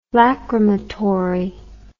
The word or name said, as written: lacrymatory